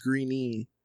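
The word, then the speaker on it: Greene: It's Green. They say Green. – Green? – Okay. Yeah. I was like, they don't pronounce the E. – That's fucking weird. – I know, because like there's just that chick from Twilight, Ashley Green. I think it's spelled the same way. – Okay. – I think that the E at the end is silent.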